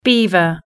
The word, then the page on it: beaver: British English